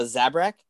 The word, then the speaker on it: Zabrak: And she's fighting a Zabrak.